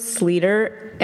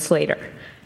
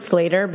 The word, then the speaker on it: Sleater: Well, I think it can go either way. We're fine with Sleater, and we're more fine with Slater. But it's technically, we pronounce it Slater, but we're okay with the Sleaters out there.